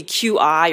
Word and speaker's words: qi: So qi can be qi or chi, depending on how you want to pronounce it.